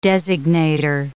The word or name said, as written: designator